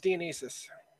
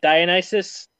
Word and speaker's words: Dionysus: Is it Dionysus or Dionysus? I always thought it was Dionysus. – Actually, it's pronounced Dionysus. – Okay. – Like Dionysus.